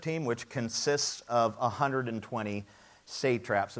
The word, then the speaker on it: satraps: And that's the right way to pronounce that. So, Cheryl, 20 points. Um, at the beginning, because it looks weird, right? Satrap? Satrap. At the beginning, Paul will probably correct me later, it is not satrap.